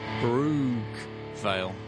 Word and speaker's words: Brookvale: Brookvale.